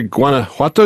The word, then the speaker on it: Guanajuato: You mentioned the Guanajuato. Is that how you say it? Is that where Diego Rivera, the great Mexican artist, was born? – Uh-huh. Touché. Yes, correct, my friend.